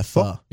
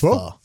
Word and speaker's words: pho: Yeah, pho. It's spelled like pho, but it's pho.